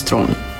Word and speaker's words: Theron: But it's the real pronunciation of my last name is Thron, which is really hard for people to say.